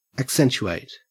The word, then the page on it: accentuate: Australian English